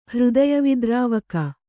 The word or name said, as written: ಹೃದಯವಿದ್ರಾವಕ